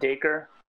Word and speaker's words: Dacre: I guess that's how you say it, Dacre.